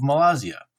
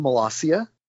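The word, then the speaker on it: Molossia: Malaysia. Have I pronounced it correctly? – It's actually Malaysia.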